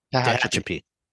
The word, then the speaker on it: Tehachapi: From Bakersfield to Tatchby, it's, you know, you're battling Wait, big how do you say the name of this town? – Tehachapi.